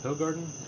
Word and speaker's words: Hoegaarden: Is it a beer, Hoegaarden? I believe that's... Is that Belgian? – Yep. – Yeah. – And I've always pronounced it Hoegaarden, but apparently it's Hoegaarden. – Hoegaarden. – Like an L.